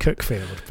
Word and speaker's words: Cuckfield: The thing is, it's pronounced Cuckfield, but it's spelt like.